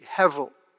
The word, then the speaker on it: hevel: Now, confusingly, that's written in English, as you'll see on the sheets, as if it has a B in the middle, but you pronounce it with a V, hevel.